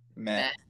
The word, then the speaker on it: meh: I don't know how I feel, so I'm going to give this a moi. A moi, I guess. How do you say it? Moi? Meh? – Meh. – That meh. There we go.